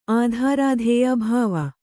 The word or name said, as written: ಆಧಾರಾಧೇಯ ಭಾವ